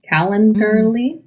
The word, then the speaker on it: Calendly: Calendly.